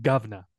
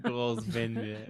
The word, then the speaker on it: Grosvenor: I think you say it like governor. Governor. – Governor Square. – Governor Square. – Grosvenor Square. Oh my god. It's French. It's Grosvenor. – Grosvenor.